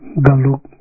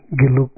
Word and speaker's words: Geluk: So then, sometimes you say Gandhian tradition or Geluk tradition is pretty much same, it's no different way of pronouncing, but Galuk, Geluk, same.